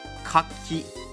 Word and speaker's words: 柿: 柿子的日文是かき、かき、かき。如果这个念成かき、かき的话，那个是那个蛤蜊，哎，不是蛤蜊，牡蛎，牡蛎，对对对对，かき是牡蛎，かき是柿子。